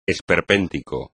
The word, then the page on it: esperpéntico: espagnol